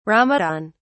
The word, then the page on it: Ramadan: British English